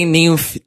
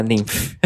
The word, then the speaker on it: Niamh: E tem Nymph também, que se escreve N-I-A-M-H. Isso fala Nymph.